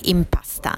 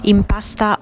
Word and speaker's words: Impasta: Marketing Manager di Impasta. Impasta, o Impasta, con una pronuncia inglese, è un prodotto innovativo che vuole presentare una nuova versione di 2 grandissimi classici italiani, la pasta e il pane.